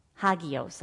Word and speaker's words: hagios: The Greek word for holiness is, I'm going to spell it again, H-A-G-I-O-S. Hagios.